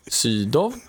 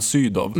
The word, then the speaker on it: Sydow: Och sen uttalar de Göran von Sydow på väldigt så här, svenskt sätt. – Sydow. – Först fransk och sen Göran von Sydow.